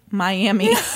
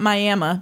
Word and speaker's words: Miami: It is, in fact, pronounced Miami, not Miami, because it's not fucking Oklahoma.